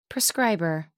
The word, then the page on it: prescriber: American English